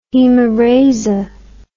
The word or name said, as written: hemerasia